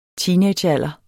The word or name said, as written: teenagealder